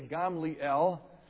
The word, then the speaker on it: Gamliel: But a Pharisee named Gamaliel, that's the Greek pronunciation. In Hebrew, you'd say Gamliel.